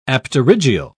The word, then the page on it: apterygial: British English